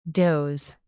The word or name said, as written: doze